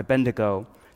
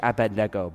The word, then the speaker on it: Abednego: It's a great story in Daniel about three friends, Shadrach, Meshach, and Abednego. It's actually Abednego, but we say it wrong and whatever.